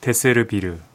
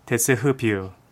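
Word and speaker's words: desservir: 프랑스어 데세르비르. – 네. – 근데 이게 실제 그 발음이 아닐 것 같아서 찾아봤습니다. 실제 발음은 데세흐비르.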